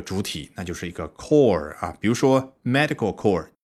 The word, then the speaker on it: corps: 这个 core 可别读错了，不是 corpse，而是 core 啊。